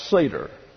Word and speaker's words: Seder: During the Passover Seder, and again, and my little postage stamp here, that this is pronounced Seder. So don't suffer from the great vowel shift.